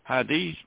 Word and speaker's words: hadith: That's spelled H-A-D-I-T-H, hadith. The words hadith and sunnah, which is another word, sunnah, spelled S-U-N-N-A-H, are considered to be synonymous in the religious literature of al-Islam.